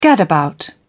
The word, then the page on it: gadabout: British English